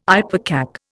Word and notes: ipecac: Indian

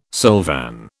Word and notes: silvan: British English

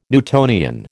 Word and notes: Newtonian: American English

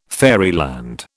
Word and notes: fairyland: British English

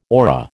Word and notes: orra: American English

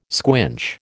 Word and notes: squinch: American English

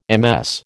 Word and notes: MS: American English

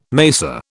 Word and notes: mesa: British English

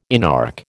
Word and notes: inarch: American English

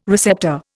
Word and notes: receptor: Indian

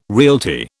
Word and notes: realty: British English

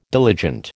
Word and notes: diligent: American English

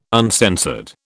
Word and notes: uncensored: British English

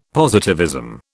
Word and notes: positivism: British English

